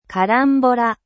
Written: carambole

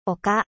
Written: colline

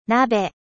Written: casserole

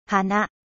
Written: fleur

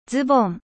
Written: pantalon

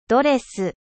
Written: ドレス

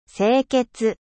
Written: 清潔

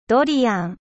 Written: durian

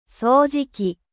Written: aspirateur

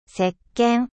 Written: 石鹸

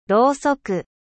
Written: bougie

